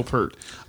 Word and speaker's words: Peart: How do you pronounce his name? – Peart. – I pronounce it Peart. – Yeah, I went to his master class, and he introduced himself as, "Hi, I'm Neil Peart."